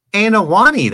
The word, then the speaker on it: Anoa'i: How do you say that, Anoa? Anoa'i, that's better. Anoa'i wrestling family.